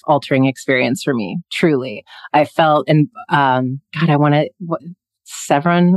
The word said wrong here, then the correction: Saffron: Rossi, am I saying her name wrong? – Saffron.